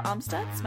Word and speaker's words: Amstutz: And you can find me on all social media platforms at Sandra Amstutz.